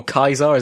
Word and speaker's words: Caesar: Call the robot Caesar. I mean, just go for it. Or Kaiser, as it would have been, like, to be perfectly historically accurate. That's how it would have been pronounced in Latin.